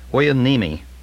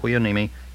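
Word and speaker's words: Hueneme: Anyway, it's H-U-E-N-E-M-E. And as in all the words in Hawaii, for instance, you pronounce every letter. Huenemi, Port Huenemi, California.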